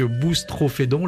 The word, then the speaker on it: Boustrophédon: Nous poursuivons la lecture de votre quatrième disque, Boustrophédon, l'ai-je bien prononcé ? – Très bien.